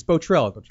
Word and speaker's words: Bottrell: It's either Bottrell or Bottrell. – It's Bottrell. It's David.